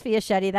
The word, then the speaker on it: Fiaschetti: Okay, so my last name is Fiaschetti. That rhymes with spaghetti, right?